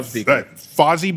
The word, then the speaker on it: Fozzie: Oh, and uh, uh, Fozzie Bear. I think is did I say that right, yes, Charles? Fozzie.